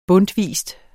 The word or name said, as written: bundtvis